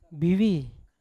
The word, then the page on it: bivi: Yawa